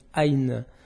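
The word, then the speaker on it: Aïn (عين): Aïn, ça prononce Aïn en arabe.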